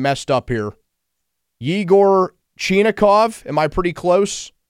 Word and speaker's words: Yegor: It's more Igor than Yegor.